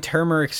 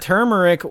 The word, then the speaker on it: turmeric: Is the R in turmeric silent? The word is spelled the word. – Okay, I can't talk right now, guys. I can't even say words. The word is spelled turmeric with an R in the first syllable, but that R is silent.